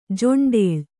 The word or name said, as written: ಜೊಂಡೇಳ್